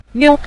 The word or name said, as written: niu5